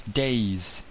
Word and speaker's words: Days: It's a G-A-V-I-N. It's a Welsh name. – Awesome. What's your last name? – It's Days. D-A-Y-S.